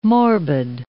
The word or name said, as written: morbid